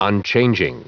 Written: unchanging

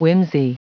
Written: whimsey

